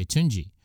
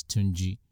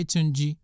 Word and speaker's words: Tunji: And so people spell it T-U-N-J-E, T-U-G-E. Some people spell it T-U-J-U. But if I say to you, my name is Tunji, then say Tunji, you know, not Tonji or Tony.